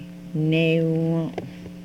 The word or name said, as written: newom